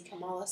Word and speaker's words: Kamala: And now I'm, like, questioning if I'm saying it right. – No, I think you are. I just want to say Kamala so bad.